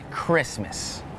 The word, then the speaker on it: Christmas: Some examples with a silent T are the words listen, the word castle, and the word Christmas.